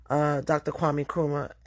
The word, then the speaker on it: Nkrumah: So my mom and I went to the mausoleum and museum of Dr. Kwame Nkrumah. And before anyone tries to check me, the N is silent, confirmed in Ghana.